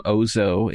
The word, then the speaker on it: Ausso: And then this individual who introduces himself as Ozzo One, I think he calls him Ozzo in the series, but it's spelled AUSSO1.